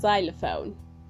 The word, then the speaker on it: xylophone: The word is xylophone. There you have the X sound, z, xylophone, not xylophone.